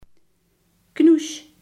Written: kraakbeen

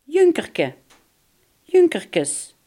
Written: duizendschoon

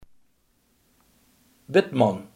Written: weduwnaar

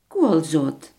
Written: koolzaad

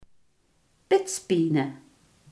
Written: grenenhout

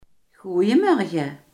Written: goedemorgen

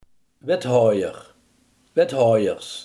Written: wethouder

